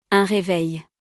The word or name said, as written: Un reveil